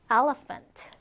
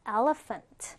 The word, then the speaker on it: elephant: elephant. 자, 지금 여기서 ph라는 부분은 프로 발음해요. 그래서 elephant. elephant. 이렇게 발음이 되죠.